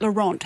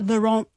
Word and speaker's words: Laurent: I don't think you pronounce the T on the end of Laurent.